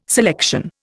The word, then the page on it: selection: Indian